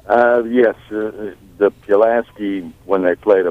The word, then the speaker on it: Pulaski: Pulaski.